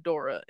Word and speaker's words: Dore: And it's not spelled Dora like Dora the Explorer. Dora the Explorer. It's spelled D-O-R-E. And so I thought it was Dore for the longest time. And then I heard it in a video and everybody said Dora.